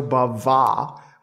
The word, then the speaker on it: Bava: Lamberto Bava, with a little an extra accent on the A there.